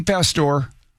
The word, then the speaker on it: Pastore: Vinny Pastore is the Italian pronunciation.